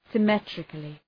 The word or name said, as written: symmetrically